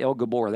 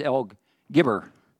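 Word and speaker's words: El Gibbor: So as we dig into that, this name, Mighty God, that is pronounced El, okay, I always want to say El Gabor. That's not right. It's El Gibber. Is how you say that.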